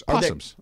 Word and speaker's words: opossums: We've got a family of, I think they're called opossums. – Possums. I think I believe the O is silent.